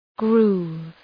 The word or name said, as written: groove